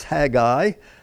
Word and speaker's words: Haggai: And if you want to look at those 5 books, Ezra and Nehemiah, Okay, and then Haggai, don't say Haggai, two syllables, Haggai, Zechariah, Malachi, at the end of your Old Testament.